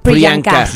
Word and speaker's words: Priyanka: Priyanka.